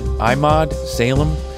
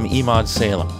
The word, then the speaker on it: Emad Salem: He pronounces it Emad Salem. His former FBI handler calls him Emad Salem. And when I asked him about it, he told me to call him Emad Salem, because he said, that seems to be the easiest for non-Arabic speakers.